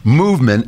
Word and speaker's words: MVMT: I'm so happy to have them, Movement, although they left out all the vowels for some reason. It's MVMT. And if we find the vowels, we'll put them back. But it's pronounced Movement, and it is Movement Watches.